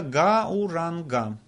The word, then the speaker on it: гауранга: Вообще на санскрите оно пишется «гауранга». То есть там в начале после «г» стоит такой звук, который обозначается двумя буквами «а» и «у», «ау». Но иногда он превращается в «о».